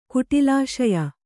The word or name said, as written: ಕುಟಿಲಾಶಯ